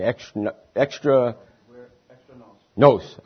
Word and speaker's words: extra nos: Extra nos.